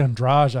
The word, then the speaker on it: Andrade: Oh, it's actually Andrade. I think is how you say.